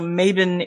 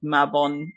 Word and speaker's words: Mabon: So Mabon is the Scots pronunciation of Mabon, the modern Scots pronunciation.